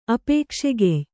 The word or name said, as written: ಅಪೇಕ್ಷೆಗೆಯ್